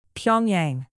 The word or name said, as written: pyongyang